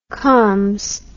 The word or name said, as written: coms